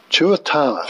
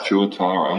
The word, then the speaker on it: Tuatara: Tuatara. – Tuatara.